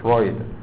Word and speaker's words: Freud: technique 当然这也是指技巧了哈，devised 啊，devised 是过去分词当形容词，是发明或是发展出来的，啊是规划设计有这个意思，devised 啊，这个后面这个是一个人名啊，Sigmund Freud 是弗洛伊德啊，那个因为他这个是德文的名字，所以那个 EU 呢在德文里面是念 oi 的音。所以念作 Freud 啊，由这个弗洛伊德呢，他所发明、所设计的这一种技巧啊。